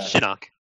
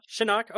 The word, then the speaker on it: Shinnok: And his father, Shinnok? I'm pronouncing that wrong, aren't I? – Shinnok, yeah. – Shinnok, okay. Played by Rainer Schoen, who was in a whole shitload of German films.